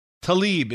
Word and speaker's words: Tlaib: One of them is Rashida Tlaib. It's T-L-A-I-B, but it's pronounced Tlaib.